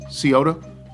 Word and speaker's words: Scioto: Senior Open at Scioto. Is that how it's pronounced? Scioto Country Club? – Yeah.